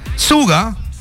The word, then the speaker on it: Suga: Suga, Suga, ok.